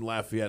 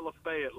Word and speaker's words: Lafayette: And thanks for pronouncing it the correct way. It's not Lafayette like it is in Alabama.